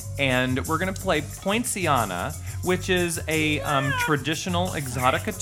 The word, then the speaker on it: Poinciana: And it sounds like poinsettia. – But it's pronounced poinciana.